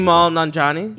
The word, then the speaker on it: Kumail Nanjiani: Kumali. I can't pronounce Kumail Nanjiani.